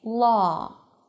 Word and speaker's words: law: We say law, law. So remember, whenever the W appears after an A, it represents together the ah as in father vowel sound, law.